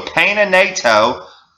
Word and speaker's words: Peña Nieto: The president of Mexico, how do you pronounce his name? Peña Nieto? I think P-E-N-A-N-I-E-T-O. Peña Nieto.